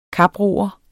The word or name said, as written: kaproer